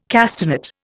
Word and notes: castanet: Indian

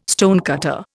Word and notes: stonecutter: Indian

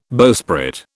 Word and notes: bowsprit: British English

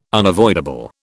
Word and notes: unavoidable: British English

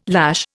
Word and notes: lash: Indian

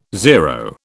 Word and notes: zero: British English

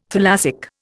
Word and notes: thalassic: Indian

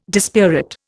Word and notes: dispirit: Indian

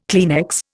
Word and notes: Kleenex: Indian

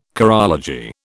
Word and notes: chorology: British English

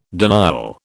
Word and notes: denial: British English

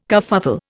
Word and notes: kerfuffle: Indian

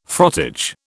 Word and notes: frottage: British English

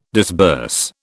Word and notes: disburse: British English

